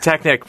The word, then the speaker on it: Technique: Am I saying technique right, or should I say technique? – Technique, pronounced the German way.